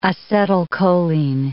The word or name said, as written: acetylcholine